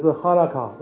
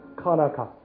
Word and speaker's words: Hanukkah: 那这个有人把它念成是 Hanukkah，那他们也会叫做 Hanukkah。